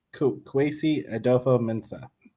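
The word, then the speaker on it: Kwesi Adofo-Mensah: Kwesi Adofo Mensah.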